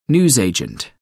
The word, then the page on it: newsagent: American English